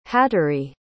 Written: hattery

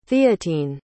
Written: theatine

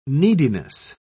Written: neediness

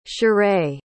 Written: charae

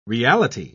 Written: reality

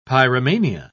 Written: pyromania

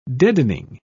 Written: deadening

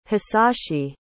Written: hisashi

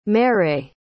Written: marais

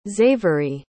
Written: zavory

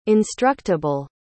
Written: instructable

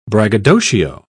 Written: braggadocio

